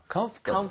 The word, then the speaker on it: comfortable: 원래 주류 발음이, 그러니까 많이들 뭐라고 발음하냐면 이 OR 발음을 안 할 때가 되게 많아요. – Comfort. – comfortable. – 아, comfortable. – comfortable. 아, 예, comfortable. 그래서 OR 발음을 좀 제거하는 것도 나쁘진 않다.